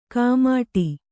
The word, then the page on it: ಕಾಮಾಟಿ: Kannada